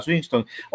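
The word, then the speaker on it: Swinton: ya está trabajando y con esta tilda Swinston, así es que se pronuncia, Swinston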